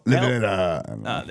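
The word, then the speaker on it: Levira: These wine tanks collapsed, sending a torrent of red wine down the small streets of Lavira. Lavira. Listen, I'm not Portuguese, so. – Lavira. – There you go. – That's exactly how they say it.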